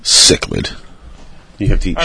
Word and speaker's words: cichlid: Cichlid? – Cichlid, yeah, mm-hmm.